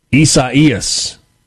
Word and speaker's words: Isaias: Right now, the timeline, somebody said that it's, Caleb in Starkville says that this is pronounced Isaias, the Spanish and Portuguese pronunciation for Isaiah.